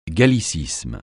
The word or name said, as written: gallicisme